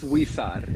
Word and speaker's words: Huizar: It's it's Weesar.